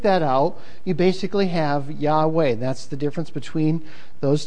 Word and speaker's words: Yahweh: People who study in schools a lot of time believe that the proper pronunciation of Jehovah is actually Yahweh.